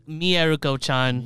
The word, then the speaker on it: Mieruko-chan: We have Maruku-chan, episode 12. – Also, I know I gotta be that guy, especially after 12 episodes. It's actually pronounced Mieruko-chan.